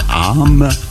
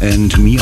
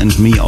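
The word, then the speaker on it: Âme: Am Anfang habe ich ihn Arm ausgesprochen. Tatsächlich wird aber And Me ausgesprochen und ich habe ihn sogar für eine Frau gehalten.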